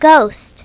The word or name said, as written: ghost